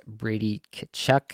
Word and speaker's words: Tkachuk: I think the next guy that I'm going to have to take here is Brady Tkachuk. I said it intentionally slowly so I could get that pronunciation right for everybody there. Brady Tkachuk, I just feel like he's so dependable here.